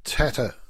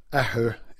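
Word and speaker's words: Athair: It is, and it's sometimes called Tatter Jack Walsh, which is a mispronunciation of the original Irish title. Aher in Irish is the word for father.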